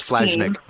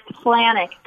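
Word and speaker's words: Flajnik: I thought it was Flashnick. – Flannick. The day is silent.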